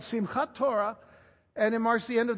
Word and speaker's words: Simchat Torah: It was a holiday added by the rabbis later on called Simchat Torah. Simchat Torah. If you want to know how to spell it, it's S-I-M-C-H-A-T. Simchat. Simchat. S-I-M-C-H-A-T. Simchat Torah. Running together, it's Simchat Torah.